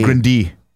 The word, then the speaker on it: Grande: You're saying it wrong, Tim. I'm sorry. It's Ariana Grande.